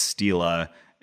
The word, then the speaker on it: stele: And I say stele. I think if you're pronouncing it the Latin way, it's stela. And plural stele, but I'm going to say stele and steles.